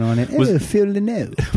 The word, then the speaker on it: Lynott: Phil Lynott. – That's how they told me in Dublin it's pronounced right.